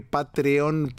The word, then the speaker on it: Patreon: Se escribe Patreon.